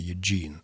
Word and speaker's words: Юджин: Если я не ошибаюсь, то это имя по-английски звучит с ударением на втором слоге «Юджин».